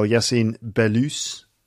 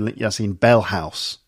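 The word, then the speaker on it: Yacine Belhousse: Yassine Belous. That's how you pronounce his name. Because I've always said Yassine Bellhouse. But it's Belous, right? – Yeah.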